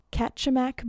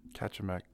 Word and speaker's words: Kachemak: And the Homer Spit is just like it's like 4.5 mile jut of land out into the Kachemak Bay. I looked up, there's a couple different ways they say to pronounce it, but I think it's Kachemak. Homer Spit. – Yeah, I think there's a Kachemak National Park, Kachemak area up there with a bunch of bears.